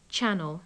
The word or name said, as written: channel